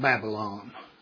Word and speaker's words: Babylon: He was the king of Babylon. I love the way Tim James pronounced it. He pronounces that baby land. So that's what it is.